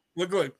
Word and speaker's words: Leglue: And yesterday, the key to me was when John LeGlue came in. Am I saying that right? – LeGlue.